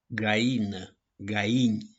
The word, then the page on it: GAINĂ: GAINI